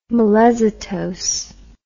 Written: melezitose

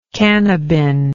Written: cannabin

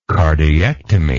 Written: cardiectomy

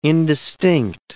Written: indistinct

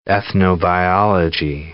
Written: ethnobiology

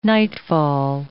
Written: nightfall

